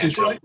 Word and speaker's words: Antoine: That's Antoine.